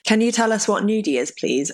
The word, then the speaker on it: gnudi: So, nudie is I hope we're pronouncing it right. I'm pretty sure it's Gnudi. There's a silent G at the beginning.